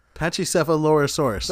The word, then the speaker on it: Pachycephalosaurus: It's Pachycephalosaurus. – Pachycephalosaurus.